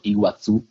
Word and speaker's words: Iguazú: E lì ci sono delle cascate fantastiche che si chiamano appunto Iguazú, con la Z e l'accento sulla U, e sono fantastiche.